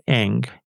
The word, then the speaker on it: Ng: And that's spelled NG, right? – NG, exactly.